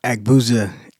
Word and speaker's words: Aigbuza: Aigbuza.